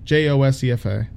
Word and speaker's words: Josefa: Josepha, Josepha, is spelled, uh, I think it's Josepha, J O S E F A.